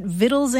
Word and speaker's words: victuals: And so they put the letter C back into it and started spelling it V-I-C-T-U-A-L. But the thing is that the old pronunciation was already so well established that people kept pronouncing it vittles anyway. So today you'll see either the word vittle spelled V-I-T-T-L-E or V-I-T-T-L-E-S, vittles, and you'll also see it spelled V-I-C-T-U-A-L-S, vittles.